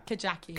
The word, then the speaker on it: Kajaki: now, how do you pronounce the film? Because I'm scared of mispronunciation around you already. – Okay, Kajaki. – Kajaki, right. That is what I would have gone for.